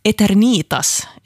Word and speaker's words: Eternitas: Där kämpar jag på lite, men Eternitas är tydligen det korrekta uttalet här och namnet betyder evighet.